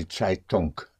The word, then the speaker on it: Zeitung: Spelled Z or Z, E-I-T-U-N-G. Die Zeitung. And the N-G in German, unlike in English, the N-G is pronounced. Zeitung. The G comes out, die Zeitung.